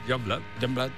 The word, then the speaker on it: Youngblood: Es que era muy difícil de pronunciar, pero luego ¿cómo se pronuncia? – Youngblood.